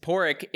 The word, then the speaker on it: Padraig: First of all, how do you spell Porrick? Why do you pronounce it Porrick? And how good is he, or not good is he, at his job? – Porrick is spelled P-A-D-R-A-I-G.